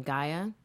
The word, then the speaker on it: Gaea: Gaia? – Mm-hmm. – G-A-E-A.